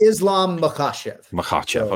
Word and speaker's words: Islam Makhachev: It's Islam Makhachev.